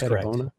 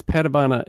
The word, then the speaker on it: Padavona: It's spelled Padavona. It's pronounced Padavona. It's just a family thing.